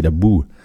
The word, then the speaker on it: boha: Ça s'écrit B-O-H-A, mais ça se dit la boue.